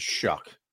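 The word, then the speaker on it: Shough: We found out his name is Shuck.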